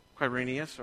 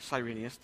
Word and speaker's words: Quirinius: This was the first census that took place while Quirinius, or Cyrenius, depending on how you want to pronounce that, was governor of Syria.